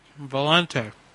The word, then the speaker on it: Valente: Valente. – And could you spell that for me, please? – V-A-L-E-N-T-E. – And that's V-A-L-E-N-T-E? – That's right, honey.